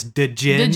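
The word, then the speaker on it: djinn: I believe I believe the correct way to say that is Dijin.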